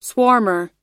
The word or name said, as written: swarmer